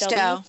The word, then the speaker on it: Cousteau: Coast, I don't even know how to pronounce this. – Cousteau. – Thank you. – From Jacques Cousteau.